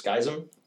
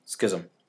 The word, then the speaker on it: Schism: Skism? – Schism. – Skyrim? – Schism. – Schism. – Schism. Say it correctly or don't say it at all.